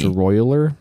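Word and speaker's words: Royler: That dude Eddie and Hoyler, Eddie and Hoyler Gracie, dude, that one is crazy. – It's pronounced Royaler.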